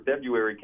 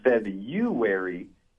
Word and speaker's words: February: Well, I was just going to say that actually the pronunciation for February can go both ways. The most common pronunciation in the United States is February, but the less common February is also an acceptable pronunciation. So technically, both of these guys are right in their pronunciation.